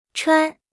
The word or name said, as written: 穿